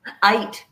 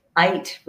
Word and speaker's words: Aydt: How do I say it? – Aydt rhymes with light. – Aydt. – Aydt rhymes with light, like Aydt.